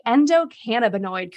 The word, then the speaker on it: endocannabinoid: Did I say endocannabinoid correctly? – You did.